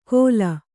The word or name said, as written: ಘೋಲ